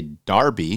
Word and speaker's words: Derby: So, like, anything that ends in B-U-R-Y is Brie. If I ask an Englishman to pronounce the word D-E-R-B-Y, they'll say Darby.